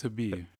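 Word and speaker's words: Khabib: So on Twitter, he pretty much took a shot at, I want to pronounce his name, Habib. – It's just Habib.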